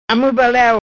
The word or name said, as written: Amui baleu